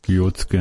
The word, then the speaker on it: Kyotské: Potom je docela zajímavá věc, že vědci z Kyotské univerzity Japonsko, Kyotské, snad jsem měl správnou výslovnost. – Kyotské, no.